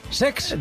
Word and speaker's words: Cesc: No lo he dicho mal, es Sex. – De Chex.